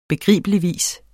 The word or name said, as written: begribeligvis